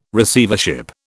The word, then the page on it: Receivership: British English